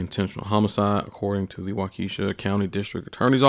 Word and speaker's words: Waukesha: Before we move to the Waukesha, is that how you pronounce it? Yeah.